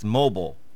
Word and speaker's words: Mobile: He told me how to say it. Nope. He said it's Mobile. That's how you say it.